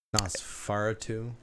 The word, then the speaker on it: Nosferatu: Nosferatu.